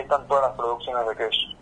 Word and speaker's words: Kesh: Es Kesh, se escribe con K y SH al final, de Kesh, como suena, y significa mirar, en tehuelche, ¿sí?